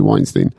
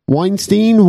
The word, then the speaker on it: Weinstein: First and foremost, the name Weinstein, Weinstein. Why is it wine on the first half, but then Steen on the second half, even though it's spelt the same way?